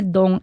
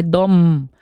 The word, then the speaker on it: dom: Aqui o próprio não é dom, é dom. – É dom. – Tem que pronunciar o M.